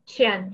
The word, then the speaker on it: Qian: Qian, yes, you got it perfectly.